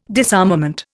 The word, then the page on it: disarmament: Indian